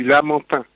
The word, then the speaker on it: Lamentin: Euh, donc vous êtes le responsable de communication à la mairie du Lamorantin en Martinique, c'est bien ça Alors, c'est la mairie du Lamentin. – Alors, on le prononce mal, désolé. – L-A-M-E-N-T-I-N. E e euh, le Lamentin, la ville du Lamentin en Martinique.